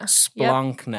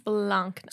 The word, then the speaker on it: Splankna: So I guess that kind of brings me to my next question of understanding what Splankna is, which am I saying that right? Splankna? – Splankna. – Splankna. – Yep. Splankna. – Splankna. Mm-hmm. Okay, we're getting there.